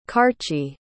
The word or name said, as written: karcsi